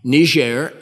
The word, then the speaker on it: Niger: There is a country now, Niger is due south. They put a French pronunciation on it because it is the lingua franca of Niger.